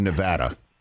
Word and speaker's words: Nevada: Nothing personal, as they say on your pod, but it's Nevada, David.